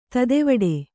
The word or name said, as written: ತೊತ್ತುವೇಟ